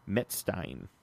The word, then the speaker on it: Metzstein: Metstein. – Metstein? Metstein. – Yeah. I even put the pronunciation.